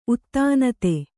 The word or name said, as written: ಉತ್ತಾನಿತ